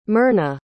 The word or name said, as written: mirna